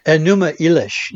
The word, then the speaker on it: Enuma Elish: Enuma Elish.